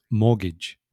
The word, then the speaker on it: mortgage: The spelling, I mean, the way you pronounce it and the way it's spelled, well, which often happens in English, is so different. So clients or students have a lot of problem. So it's mortgage. So it's got mortgage, if you want to, phonetically. – Yes. That's right. But it's pronounced mortgage, which doesn't bear any resemblance really to the word you see written.